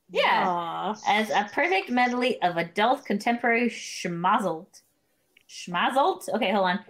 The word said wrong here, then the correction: schmaltz: S-H-M-A-L-T-Z. Schmaltz? How do you say that, D? – I'm sorry, I don't know. Schmazelt? What is that? – Schmaltz. – That was a schmaltz.